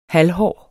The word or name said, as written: halvhård